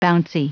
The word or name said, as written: bouncy